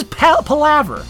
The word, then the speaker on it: palaver: How you pronounce it? – Palaver. – Okay, it's an actual word, sir. And if you look up the pronunciation in the dictionary, which it's I finally did, palaver. – It is palaver. It is palaver, sir.